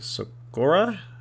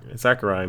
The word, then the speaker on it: Sakurai: Um, I think uh, it was that um, Mashiro Sakura. Sakurai? Sakurai? Sakurai.